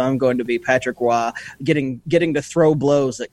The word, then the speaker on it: Roy: This one is just for Drew, too, because I'm going to quantum leap into Patrick Roy. When they I appreciate you said his name right.